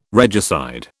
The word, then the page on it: regicide: British English